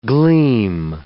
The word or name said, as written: gleam